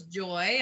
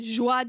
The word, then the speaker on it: Joie: I thought it was Joy. I didn't know. – As did I. – I looked up the pronunciation as in joie de vie. It's joie.